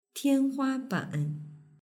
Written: tianhuaban

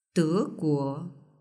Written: deguo

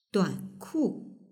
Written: duanku

